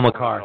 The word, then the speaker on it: Makar: Uh, Cale, uh, I always butcher his name. I want to say it's Maker or it's Malaker or. Um, but so they they got some talent Cale Makar. over there. – That's it.